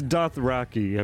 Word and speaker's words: Dothraki: All right, I'm looking up Dakthorian. – Dakthorian. – It's Dothraki, I believe.